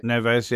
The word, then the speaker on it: Neves: We're used to Wolves signing quality players like, I can't pronounce his name, Neves, is it? – Neves, yeah.